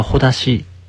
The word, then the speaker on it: 絆: まあその絆というのが、読み方を変えればほだしという読み方になりますけれども、まあ情にほだされるという言い方をしますが、まあね、やっぱり関わりを持ってしまうとなかなかに、その人との縁を断ち切れなくなるというところありますけれどもね、やっぱりでもそういうところ、まあやっぱりあると思うんですよね。